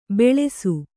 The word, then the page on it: ಬೆಳೆಸು: beḷsu